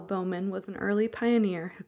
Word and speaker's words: Bauman: A journalist in 2014 attempted to identify Bowman. Of course, we only have a last name, and it is spelled the German way, so B-A-U-M-A-N, Bowman.